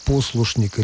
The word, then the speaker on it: послушник: Ну вот, может быть, к сожалению, Олег Николаевич уже не в эфире, можно было бы его спросить, как бы он поставил ударение в слове «послушник» или «послушничество». Сегодня почти невозможно услышать традиционное правильное ударение на первом слоге.